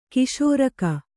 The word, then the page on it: ಖಾಸರೋಗ: kannada